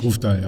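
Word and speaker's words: Grøvdal: Es wird auch ein sehr spannendes Debüt geben, nämlich mit von einer norwegischen Athletin, nämlich Caroline, ich glaube, Grofdal spricht man so aus, richtig? – Grofdal, ja.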